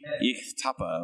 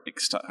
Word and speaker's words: Ixtapa: It's right next to the Ixtapa, or Ixtapa, however you pronounce that. Because they pronounce the X like an H.